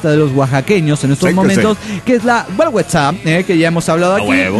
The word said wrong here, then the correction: Guelaguetza: Nada más que la pronunciamos muy mal. Helaguetza, dice, así se lee, pirata, mi amor. Sí, pero no sé si lo estoy leyendo bien porque sería como guerra, ¿no? Entonces sería... Guelaguetza, la Guelaguetza, donde todas las tradiciones e historias se conjugan en estas dos semanas.